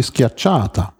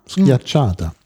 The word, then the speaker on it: Schiacciata: Ach, die Schiacciata. – Schiacciata. Das ist so ein Art Fladenbrot, oder? – Ja, ja. Schiacciata.